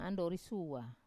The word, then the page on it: andori sua: Wamesa